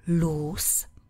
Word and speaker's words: loose: A opakem je přídavné jméno loose. Volný, neutažený, nepřipevněný. Pozor, píšeme s dvěma O a vyslovujeme se s. Loose. Abyste si ho nespletli se slovesem prohrát, ztratit. Lose. To lose.